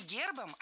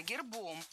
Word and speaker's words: гербом: Не гербом, а гербом. В этом слове при склонении ударение переносится на окончание. Поэтому гербом, гербами, гербах, гербы похожи на рассказчиков судьбы, семейные фамильные гербы.